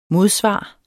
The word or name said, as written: modsvar